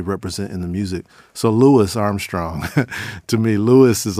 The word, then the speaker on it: Louis: Now, I say Louis because I know the prominence of syllables in Black language and where we decide to drop a syllable at the end of a word and when we decide to enunciate every part of someone's name because of the respect we have for it, right?